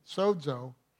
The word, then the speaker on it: sozo: Now it sounds like it's spelled S-O-Z-O, but it sounds like it has a D in it, but it's stated sozo.